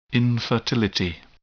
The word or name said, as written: infertility